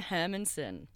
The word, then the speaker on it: Herrmannsen: Most people don't because it's terrible. So Simone Herrmannsen. – Herrmannsen. – Double R's, double N's.